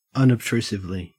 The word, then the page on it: unobtrusively: Australian English